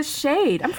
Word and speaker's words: Schade: Victoria Shade. I'm pronouncing that right? – You are. I always say it's like lampshade. – There. Well, yeah. There you go. Yeah. S-C-H-A-D-E.